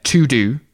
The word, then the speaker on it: Teux Deux: That it's spelt very funny, but it's to-do, but it's spelt T-E-U-X-D-E-U-X. – Hang on, T-E-U-X. D-E-U-X, and I'll drop a link in the show notes.